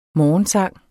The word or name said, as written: morgensang